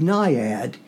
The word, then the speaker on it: naiad: Now, the word Nyad, and that's spelled in the poem, N-A-I-A-D,